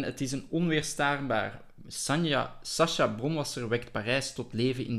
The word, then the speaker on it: Sacha Bronwasser: Het is een luister van Sasha Bronwasser. Eh, ik weet niet, we zullen na het lezen zien of ik het juist heb uitgesproken, haar naam. – Perfect.